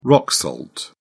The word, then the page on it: rock salt: British English